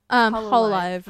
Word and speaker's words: Hololive: Hololive? – Hololive.